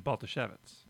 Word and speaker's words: Bartoszewicz: You put an emphasis on the O, so you're like Bartoszewicz.